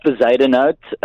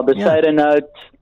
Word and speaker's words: Bezuidenhout: So it's called Bezuidenhout. – Bezuidenhout. Okay, that's... – Yeah, Bezuidenhout.